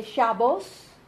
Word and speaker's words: Shabbos: They would say Shabbos instead of Shabbat. Yeah, good Shabbos to you instead of good Shabbat.